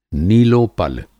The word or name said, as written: नीलोपल